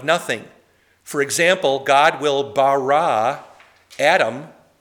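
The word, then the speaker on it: bara (ברא): The verb in Hebrew for create is pronounced, let's all say it with me, or after me rather, it's pronounced bara. Let's all say bara. Bara, that's the word for create.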